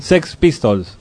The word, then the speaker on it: Sex Pistols: ¿Lo pronuncié bien? – Sex Pistols. – Eso.